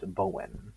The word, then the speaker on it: Boyne: Boyne in Irish is pronounced Bowen, and in Irish mythology, all around Ireland, there's a spirit realm called Otherworld.